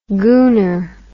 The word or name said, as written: gooner